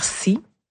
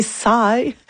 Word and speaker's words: CY: Puis en musique, on écoute le projet, j'espère que je vais bien le prononcer, là, CY, ça doit faire SI. – Ça doit. – Merci. – Pour moi, je serais pas d'une grande aide sur ça, là. – C'est Sai. C'est Sai qu'on dit? – Oui, Sai.